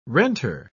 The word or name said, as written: renter